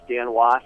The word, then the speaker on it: Dan Wos: Uh, the name's Dan Wos,